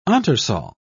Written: entresol

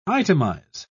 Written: itemize

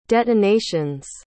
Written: detonations

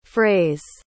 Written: fraze

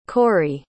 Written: Corie

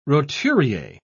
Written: roturier

